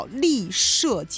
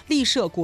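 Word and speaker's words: 栎社: 那这个字是多音字，它也念乐，但是在这个机场的名字里边，它就念丽啊，叫丽舍机场，社会的社，丽舍国际机场。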